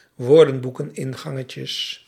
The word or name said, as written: woordenboekingangetjes